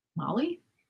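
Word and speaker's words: Mali: How would you pronounce that? – Molly. – That's Mally? the right way to do it.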